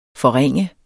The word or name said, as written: forringe